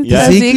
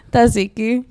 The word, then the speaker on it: Tzatziki: Oh, Tzatziki? Is that how you say it? – Tzatziki.